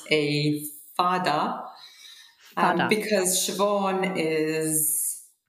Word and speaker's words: Siobhan: And then they put a fada, which is like a line over the A to make it sound more like ah. So then you have Siobhan.